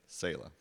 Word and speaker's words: Selah: I think it's Selah, right? Selah? Anybody know this group? Selah, thank you.